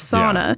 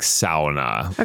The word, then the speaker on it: sauna: Well, yeah, it's basically, that's the Finnish pronunciation. So I've been hearing it wrong. – You've been hearing it wrong. Okay. Americans have maybe made it into sauna, like this kind of, you know, S-A-W-N-A kind of pronunciation. – We've just simplified it, as is our way. – When you write the pronunciation down, it's S-O-W, like sauna.